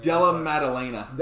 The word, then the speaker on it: Della Maddalena: Jacob Delaman... I can't say his name. – Della Maddalena. – Della Maddalena had a wicked knockout, too.